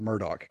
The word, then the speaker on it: Murdaugh: And they pronounce it as if there's a K on the end of Murdaugh. So they pronounce it Murdaugh, kind of like Murdaugh from the A-Team.